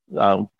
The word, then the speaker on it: Gougeon: Goujon is spelled G-O-U-G-E-O-N, Streamliner. – Say it one more time, and then we got to go. Say it one more time. – 1937, Goujon Streamliner.